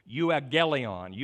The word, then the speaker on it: euangelion: It's pronounced euangelion.